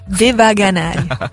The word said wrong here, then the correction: Devanagari: Anzi, Devanagari, scusate, mi faccio sbagliare a dire.